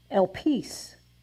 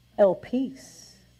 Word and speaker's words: elpis: It is pronounced elpis. Let's say it. Elpis. E-L-P-I-S.